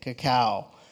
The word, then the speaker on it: cacao: Or cacao, sorry. That's technically probably the right way to say it, cacao.